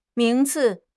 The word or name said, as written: 名次